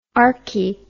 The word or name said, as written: archi